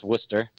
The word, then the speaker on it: Worcester: And you know, it kind of fell into a situation where I was working out with Worcester, and it's pronounced Worcester. – Oh, Worcester. – Worcester, yeah. But that's the Northeast accent for you.